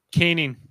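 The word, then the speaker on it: Koenig: Caning. – Caning? Is it Caning? Okay. Caning. I always say Caning or something like that. – It's like one of those names like Taves that doesn't sound like it's spelled. – Yeah, fair enough.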